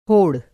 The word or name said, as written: होड़